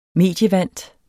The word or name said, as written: medievant